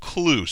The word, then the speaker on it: Klug: His last name is spelled K-L-U-G, but pronounced Clue, so that's very helpful for a radio guy.